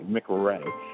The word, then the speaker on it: McRay: My last name is spelled M-C-R-A-Y, McRae.